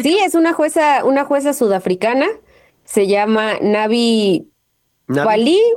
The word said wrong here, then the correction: Pillay: Pillay, así se pronuncia.